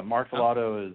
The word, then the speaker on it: Martellotto: Yeah, Martellotto is the American pronunciation that works.